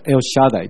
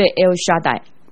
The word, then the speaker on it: El Shaddai: 有人直接把希伯来文音译认为他读作 El Shaddai，就是以利沙代。 – 他的希伯来文就是读作 El Shaddai。